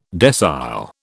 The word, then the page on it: decile: British English